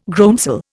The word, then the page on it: groundsel: Indian